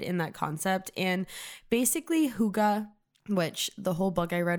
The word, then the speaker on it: Hygge: Now, if you've ever walked by a bookstore or seen this on Amazon or whatever, it is pronounced Hygge. So maybe you're like, what the fuck is Hygge? It's Hygge, but that's how they pronounce it in the Danish world.